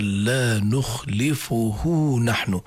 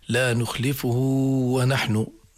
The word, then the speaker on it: لا نخلفه نحن: الحروف التي أضافوها تنطق مع الواو الأصلية، فهنا كما قرأ الأخ قرأ سليمة طبعا، فاجعل بيننا وبينك موعدا لا نخلفه نحن، حتى لا يأتي واحد يقول لي لا نخلفه ونحن، نقول له لا.